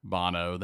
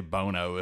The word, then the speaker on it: Bono: people could be confused and they might not realize Bono is pronounced Bono. They might say Bono if they saw it, like Sonny Bono.